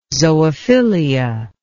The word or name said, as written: zoophilia